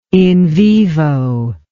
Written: in vivo

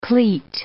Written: cleat

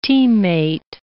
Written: teammate

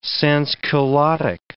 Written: sansculottic

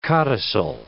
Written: codicil

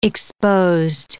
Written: exposed

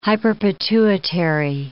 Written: hyperpituitary

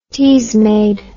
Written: teasmade

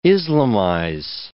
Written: Islamize

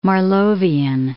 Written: Marlovian

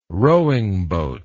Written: rowing boat

